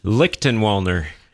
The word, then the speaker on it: Lichtenwalner: Lichtenwalner.